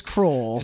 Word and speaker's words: Kroll: His name rhymes with mole. – It is. Oh, it does rhyme with Kroll. – It is Kroll.